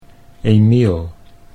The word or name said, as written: Emiel